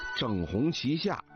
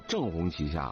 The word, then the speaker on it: 正红旗下: 老舍先生有一本小说的名字叫整红旗下啊，很多人都给读成了正红旗下了啊，实际上是错的啊。